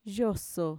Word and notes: yoso: Mixtec

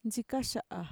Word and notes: ntxikaxa'a: Mixtec

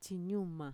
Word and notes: txiñuma: Mixtec

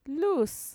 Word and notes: luus: Mixtec

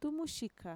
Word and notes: tumuxika: Mixtec

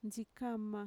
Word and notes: ntxikama: Mixtec

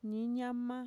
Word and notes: niiñama: Mixtec

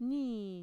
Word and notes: nii: Mixtec

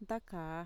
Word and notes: ntakaa: Mixtec

